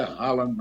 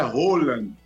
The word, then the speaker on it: Haaland: Aliás, a palavra, o nome não é Haaland, não. É Roland, viu, Tom Barros? A pronúncia é Roland.